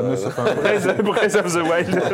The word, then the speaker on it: Breath of the Wild: C'est que je prononce bien ou pas, parce que... – On dirait Bretagne of the Wild, ouais, mais ouais, c'est ça. – Breath of the Wild, c'est bien.